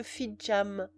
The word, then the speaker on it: Fitzjames: Mon grand-oncle Fitzjam n'avait rien qui étonnât, car on sait que les Fitzjames proclament volontiers qu'ils sont de grands seigneurs français et ne veulent pas qu'on prononce leur nom à l'anglaise.